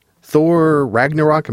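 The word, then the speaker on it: Thor: Ragnarok: Thor Ragnarok, am I saying that right, Rebecca? – Exactly.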